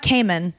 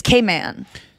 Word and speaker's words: Cayman: That's how I learned how to say it. So it's not Cayman, it's Cayman.